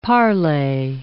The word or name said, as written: parlay